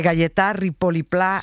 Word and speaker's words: Gaietà Ripoll i Pla: de Gaietà Ripoll i Pla, sí.